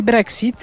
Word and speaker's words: Brexit: I teraz jeśli chodzi o Brexit, bo ja jednak chyba bym wymawiała tutaj ksy, a nie gzy, czyli Brexit, było pytanie o to, dlaczego Brexit piszemy wielką literą.